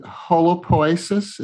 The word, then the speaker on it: holopoiesis: And I looked at the home page and the notion of, is it holopoiesis? Is that how you pronounce that? – Mm-hmm.